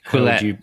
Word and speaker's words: Quillette: Now, I saw your article, which you wrote on, I'm going to mispronounce, Quillette or Quillette? – Quillette, yeah. – Quillette, right, okay.